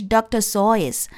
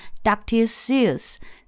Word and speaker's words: Seuss: 好，那么苏斯博士的名字呢，我们念成 Dr. Seuss。实际上呢，他正确的发音应该是 Dr. Seuss。因为他的名字是德语的名字，但是呢，因为所有的美国人都按照英语的读音去读他的名字，所以呢，大家现在都统一读成 Dr. Seuss。